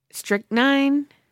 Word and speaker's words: strychnine: And her man was angry, jealous, and handed Robert Johnson a bottle of whiskey, but it was laced with strychnine. – Mm. – Question mark is, did I say that right? – Okay. Yes.